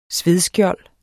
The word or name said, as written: svedskjold